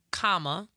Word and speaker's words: Kamala: All right, so as she pointed out, and our babies and their baby voices pointed out, her name is pronounced Kamala, like a comma, and then la.